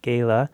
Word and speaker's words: gala: All right, next up on Express Yourself, I'm joined by Symphony in the Valley to preview the Great Gatsby Benefit Gala. I always get tripped up. Is it gala or gala? – Oh, gosh. Both. I think you can pronounce it either way. – Either way. It's up to either way a is person's fine.